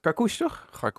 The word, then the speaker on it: Karkoes: Karoes, die... hoe spreek je dat eigenlijk uit? – Karkoes, toch? – Karkoes.